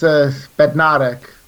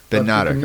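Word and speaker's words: Bednarek: Yeah, it's Bednarak. – Bednarak, okay. – I'm sure you'll pick it up. It's actually one of the simpler Polish surnames to pronounce. So you've got no, you know, there's no S, Z's and C's and various things.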